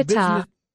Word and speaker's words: Qatar: Is it Qatar, like guitar, the musical instrument, or Qatar, like the British pronunciation of Qatar, a Fleming sore throat, Qatar? What about the Qatar. – Business? There you go.